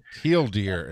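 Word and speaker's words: TL;DR: Teal deer is how it's pronounced, but go ahead.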